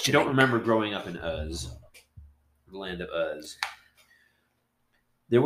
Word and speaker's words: Uz: That's spelled U-Z, ladies and gentlemen. – Not Oz, Uz.